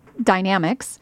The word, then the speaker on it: Dynamex: Back in May of 2018, the California Supreme Court decided a case that's lovingly known in legal circles as Dynamex, which I learned on Monday is actually pronounced Dynamics.